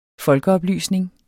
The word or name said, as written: folkeoplysning